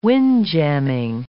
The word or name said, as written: windjamming